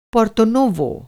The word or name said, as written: पोर्तो-नोवो